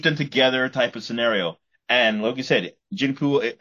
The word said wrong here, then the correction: Jinpei: I mean, I'm going to say his name properly again. Let me say that. Jinpei.